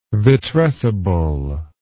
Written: vitrescible

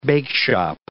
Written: bakeshop

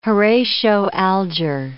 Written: Horatio Alger

